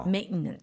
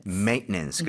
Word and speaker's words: Maintenance: Maintenance. – Maintenance. 그래서 여러분들 중간에 TE는 거의 생략하는 느낌으로 가주면은 발음이 조금 더 좋아지지 않을까. 그렇죠? – Maintenance.